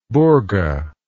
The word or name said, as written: burga